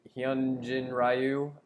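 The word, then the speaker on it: Hyun-jin Ryu: I mean, they probably have a Cy Young candidate in Hyunjin Ryu.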